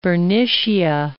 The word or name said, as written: Bernicia